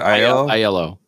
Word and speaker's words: Aiello: Aiello.